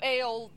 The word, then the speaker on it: Eöl: But um, so Eöl, this dark elf, sees her and he's like, "Oh my gosh, she's so fucking hot!"